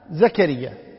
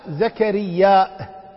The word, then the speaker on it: زكرياء: وفي قراءة: وكفلها زكريا، وفي قراءة: زكرياء بالهمزة. زكريا في القرآن كله، زكريا تنطق زكريا، مثل نبي نبي، النبيون النبيين والنبيون، والنبيين والنبيين. فالقصد أن زكريا فيها قراءتان: زكريا وزكرياء.